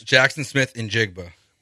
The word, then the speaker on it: Jaxon Smith-Njigba: Jaxon Smith-Njigba.